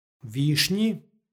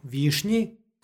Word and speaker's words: višnji: Ovo je pridev, višnji. Ekvivalent u srpskom je sa nj, višnji.